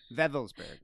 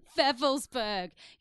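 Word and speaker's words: Wewelsburg: The castle, where's the Wevelsburg. – Wevelsburg. You know, it's because it's spelt with a wee-e, wee-e.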